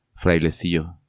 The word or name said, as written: Frailecillo